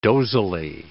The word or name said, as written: dozily